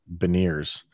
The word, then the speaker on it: Beniers: And then I think you pronounce his last name, Beniers.